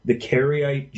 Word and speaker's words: Karaite: I wonder if you could tell the listeners a little bit more about, and I hope I'm pronouncing it right, the Karaite Jews.